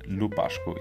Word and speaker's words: Lupascu: And by the way, if you want to check my channel out, just go to Paul and then Lupascu.